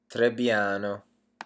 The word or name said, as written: trebbiano